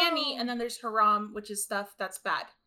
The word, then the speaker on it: haram: Forbidden or illicit in Arabic is actually, I believe it's haram. – Oh, that's how you say it.